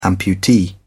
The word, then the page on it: amputee: British English